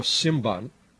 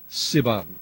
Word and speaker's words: Symban: It's in the Greek language, one of those ethnic stations in Australia, called Radio Symban, S-Y-M-B-A-N, except I don't think that's the correct way to pronounce it, because in modern Greek, it's spelled sigma, upsilon, mu, pi, alpha, nu, and the M-P digraph is actually pronounced simply as we would pronounce a B, so it should be Siban. Powers only 1,000 watts into a 20-meter high antenna at Peats Ridge, P-E-A-T-S, 60 kilometers north of Sydney.